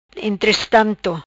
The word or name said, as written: Entrestanto